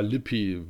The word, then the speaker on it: Lippe: Heißt er, wird er dann im Englischen auch Count Lippe ausgesprochen oder, oder Lippi? – Ja, Lippi werden, sagen sie dann eher dazu. Das ist halt dann die englische Aussprache.